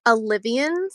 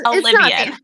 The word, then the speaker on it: Alivien: It is pronounced Alivien, so it's pronounced how it's spelled.